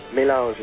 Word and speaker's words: Mélange: And the CD is called Mélange. And am I pronouncing it the way you'd like it pronounced? – Mélange is good.